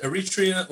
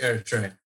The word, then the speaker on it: Eritrea: Is that how you pronounce it? Eritrea? – Right. There's people either pronounce it Eritrea or Eritrea. – How are you really supposed to pronounce it? – I pronounce it Eritrea.